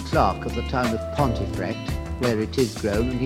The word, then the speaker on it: Pontefract: And this is a description of the licorice fields at Pontefract, which may or may not be pronounced Pumfret, in Yorkshire.